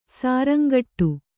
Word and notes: ಶೃಂಗಾಟ: śřngāṭa

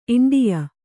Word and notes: ಇಂಡಿಯ: iṇḍiya

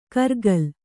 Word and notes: ಕರ್ಗಲ್: kargal

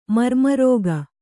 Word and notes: ಮರ್ಮರೋಗ: Kannada

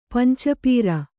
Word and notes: ಪಂಚಪೀರ: Kannada

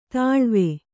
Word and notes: ತಳಿವು: Kannada

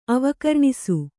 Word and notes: ಅವಕರ್ಣಿಸು: avakarṇisu

